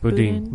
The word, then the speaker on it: Boudin: How do you pronounce it? – Boudin. Boudin. I was calling him Who Bowden.